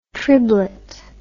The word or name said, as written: triblet